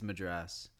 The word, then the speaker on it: Madras: Yeah, it's well, these days it's Madras. It's a bit of a family argument.